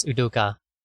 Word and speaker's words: Udoka: They have hired a coach after flirting with Udoku. They have since you know what, we've been pronouncing it wrong. It's Udoka.